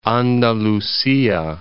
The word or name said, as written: Andalucia